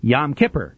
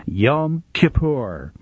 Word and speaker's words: Yom Kippur: Actually, in popular usage, most Jewish people today say Yom Kippur, but the more correct pronunciation is Yom Kippur.